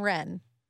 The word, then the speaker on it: Wren: And Wren. W-R-E-N. – Because that's a silent. – Like the bird. Yeah. – That's a silent W.